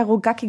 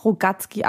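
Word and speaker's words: Rogacki: Da wird ja Rogaki geschrieben, aber anscheinend spricht man ihn doch Rogatski aus.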